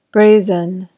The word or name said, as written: brazen